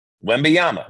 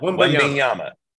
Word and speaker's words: Wembanyama: Before I let you go, as broadcasters, we always want to make sure that we pronounce players' names correctly, because you don't want to look like an idiot. So practice this. Victor Wembanyama. – Wembanyama. – Wembanyama.